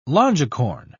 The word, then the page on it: longicorn: British English